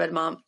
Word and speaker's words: edamame: That's one way to describe edamame.